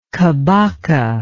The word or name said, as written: kabaka